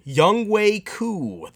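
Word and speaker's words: Younghoe Koo: Young Wei Koo, the onside kick master.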